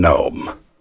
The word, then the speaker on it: gnome: Have you ever seen a garden gnome? Gnome is spelled with a silent G. Penny and I have a lifelong friend whose name is spelled S-A-R-A.